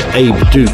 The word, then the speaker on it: Abeduque: It's a remix by Abe Duke. It's spelled A-B-E-D-U-Q-U-E.